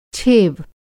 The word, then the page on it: छेव: Hindi